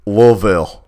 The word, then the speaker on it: Louisville: I need to pronounce none of these letters correctly to make this word, and I need to throw an extra L in there. Right. Louisville.